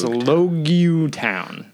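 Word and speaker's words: Loguetown: Straw Hats show up in Logtown, which I thought was Logtown, but it's actually Logtown. – Yep. – The city of Log the beginning. – Town? They kept saying Logtown. – Well, it's Oh, it's spelled reads, Logtown. – When you read it, yeah, it looks like Logtown, but which is how I thought it was spelled. But yeah. Of course, it's pronounced Logutown.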